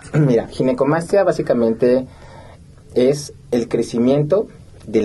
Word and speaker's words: ginecomastia: Pues muchas gracias y sí, definitivamente creo que es una, para empezar la palabra, le decía, ¿cómo se pronuncia? Ginecomastía, ginecomastia. – Sí, exacto. Es ginecomastia. Exactamente.